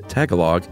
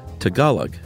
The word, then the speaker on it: Tagalog: Chuck said Tagalog It's actually Tagalog.